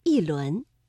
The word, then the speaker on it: 一: 第二句“ 一轮明月”，这里的“一” 读四声“ 一轮”。